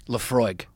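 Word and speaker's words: Laphroaig: Lafroig.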